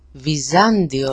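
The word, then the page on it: Βυζάντιο: viza-ndio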